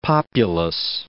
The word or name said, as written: populous